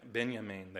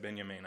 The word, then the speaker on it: Benjamin: Benjamin. They start, Ted Smith said Benjamin. I thought, oh, shoot, I had it wrong.